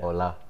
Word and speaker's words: Ola: Ola.